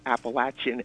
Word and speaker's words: Appalachian: And the great writer Manly Wade Wellman once said to me, it is pronounced Appalachian as if you say, I threw an apple at you.